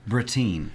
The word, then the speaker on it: Bratten: Bratten.